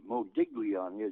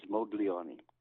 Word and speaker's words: Modigliani: It's M-O-D-I-G. Modigliani is what a lot of people call him, but the real pronunciation is Modigliani.